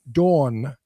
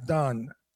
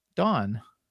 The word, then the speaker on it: Dawn: That was pronounced Dawn. He pronounces Dawn as Don. – Dawn. – It's not D-O-N. That's D-A-W-N. – A man. She's Dawn. Pronounce the W. Dawn. Dawn.